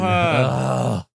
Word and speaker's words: Wuhan: Wuhan.